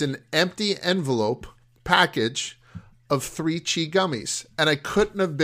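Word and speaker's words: 3Chi: It's 3-CHI, pronounced 3 Chi.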